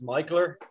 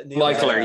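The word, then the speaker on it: Mikkeller: McKellar is McKellar Danish? I think it is. – Yeah. That's the big one. – Yeah, it's actually pronounced Michaeler. – Michaeler, yeah.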